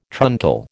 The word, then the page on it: truncal: American English